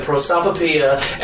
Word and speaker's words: prosopopoeia: And he says, the morrow is here introduced by a prosopopoeia. How do you say that, Wendy? – I don't know. – Prosopopoeia. – Yeah, that's right.